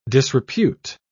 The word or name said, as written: disrepute